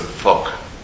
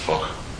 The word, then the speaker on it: Foch: Probably pronounced it Foch, F-O-C-H. Foch, Foch, like that. But the French say Avenue Foch.